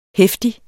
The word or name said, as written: heftig